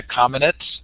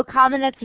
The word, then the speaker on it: Kamenetz: Kamenetz is probably more correct from a Slavic point of view, but we say Kamenetz.